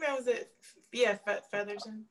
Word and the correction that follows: Featherington: Yes, the Featherington family.